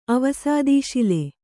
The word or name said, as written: ಅವಸಾದೀಶಿಲೆ